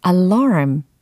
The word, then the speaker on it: alarm: 자, alarm. 이게 영어 발음이 되겠죠? alarm, alarm, alarm. 알람이 아니고 alarm 이렇게 됩니다.